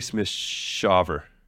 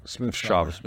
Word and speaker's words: Smith-Shaver: I always say his last name wrong. AJ Smith-Shaver. – Smith-Shaver.